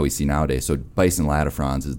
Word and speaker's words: latifrons: So one animal that I've been just completely enamored with is the bison latifrons. I'm not sure how familiar you are with, am I pronouncing that correctly? – Yeah, latifrons, right.